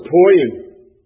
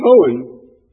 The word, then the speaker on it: Poyen: I remember when we first moved there, we went through a town that spelled P-O-Y-E-N. Somebody give me a stab at how you would pronounce that. What? Poen? Says I was poying, but it's Poen.